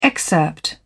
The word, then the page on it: excerpt: British English